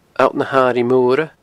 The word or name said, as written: alltnahairighemoire